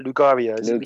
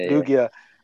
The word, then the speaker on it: Lugia: And Mew as well, or I can't say his name right, Lugaria. – Lugia.